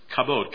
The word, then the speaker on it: kabod: The Old Testament word is kabod, K-A-B-O-D.